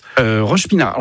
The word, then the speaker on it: Roche-Pinard: Alors, Roche-Pinard, c'est ça, c'est bien comme ça qu'on prononce. – Roche-Pinard.